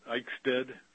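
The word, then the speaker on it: Eichstaedt: Yeah, that's good. Eichstadt, Eichsted. It's, most people just avoid it and call me Mr. Peter.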